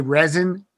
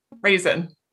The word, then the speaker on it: Rezin: Resin? – Yeah. – Because your name is spelled R-E-Z-I-N, and that's how I pronounced it the first time.